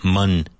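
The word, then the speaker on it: Mon: Mun. It's the same pronunciation as money. Money.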